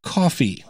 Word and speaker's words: Ko-fi: And I finally did catch on why Buy Me a Coffee and Ko-Fi are so similar, because it dawned on me that Ko-Fi could also be pronounced Coffee.